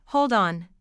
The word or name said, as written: hold on